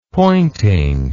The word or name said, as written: pointing